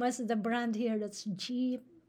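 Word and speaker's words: dyip: Wait, so jeep, which we spell it D-E-E-P, D-Y-I-P, jeep. We don't say J-E-E, because Jeep is a brand. Yeah. Okay.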